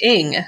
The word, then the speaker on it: Ng: Oh, I'm getting word that it is pronounced Ing. So Celeste Ing is her name.